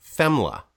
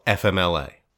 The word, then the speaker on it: FMLA: I'm saying that for a reason in that way, because Dennis and I both pronounced that FEMLA. – FEMLA, the Family Medical Leave Act. – The Family Medical Leave Act. Others often say, and we do hear this as well, FMLA.